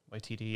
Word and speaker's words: Waititi: I always say his name wrong. Tiki Waititi. – Waititi, yeah.